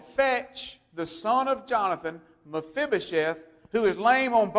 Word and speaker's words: Mephibosheth: And we find out that his name was Mephibosheth. Mephibosheth. You have to practice a little while to say that properly. Mephibosheth.